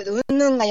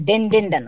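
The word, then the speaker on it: う ん ぬ ん (云々): えね、でんでんだんって、うんぬんが読めなくて、でんでんだのね、え姿勢の人って読めなくてね、 C の人って読んだ。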